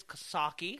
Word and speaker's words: Coxsackie: Oh, what about Coxsackie. Oh. I know I don't say that right. It's Coxsackie. If you're a native of this side of the river.